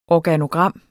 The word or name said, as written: ORGANOGRAM